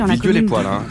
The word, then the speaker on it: Villedieu-les-Poêles: Ce train arrivera en gare de Villedieu-les-Poêles. Assurez-vous de ne rien oublier à bord. – Alors voilà, j'étais donc près de Villedieu-les-Poêles. – Villedieu-les-Poêles, les poêles à frire.